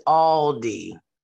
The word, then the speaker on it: Aldi: It is Aldi.